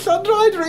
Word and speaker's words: Llanrhaeadr: Llanrhaeadr. – Okay. – Yes, Llanrhaeadr in Wales, in Powys, deepest, darkest Powys in Wales.